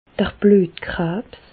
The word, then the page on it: d'r Blüetkrabs: Alsatian; Bas-Rhin; Reichshoffen